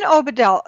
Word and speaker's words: Obadiah: Okay, so Dean Obadiah, did mm-hmm.